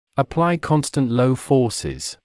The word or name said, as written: apply constant low forces